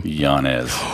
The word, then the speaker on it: Llanez: Llanez. – Wait, that's what I said, right? – No, he said two L's make a Y.